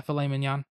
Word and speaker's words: filet mignon: That is a filet mignon. Is that how you say it? – Yeah, filet mignon.